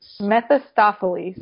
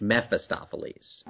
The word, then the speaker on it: Mephistopheles: Mephistopheles. No, Meph. M-E-P-H. – Mephistopheles.